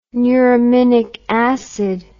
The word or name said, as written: neuraminic acid